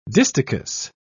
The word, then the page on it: distichous: British English